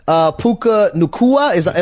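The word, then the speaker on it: Puka Nacua: And also Puka Nakua. Am I pronouncing his name right? – Right on it. N-U-C-U-A.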